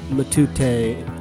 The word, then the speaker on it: Matute: Louis Matute, a name of Honduran provenance, but pronounced with a more than vaguely French intonation, perhaps, from Geneva hailing Louis Matute.